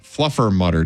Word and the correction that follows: Fluffernutter: Day. – Nope. Try again. – Not for you? – You're still saying it wrong. – Fluffernutter Day. Fluffernutter Day. Happy Fluffernutter Day.